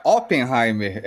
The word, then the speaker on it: Oppenheimer: tudo é que a pronúncia original é Oppenheimer. É com O.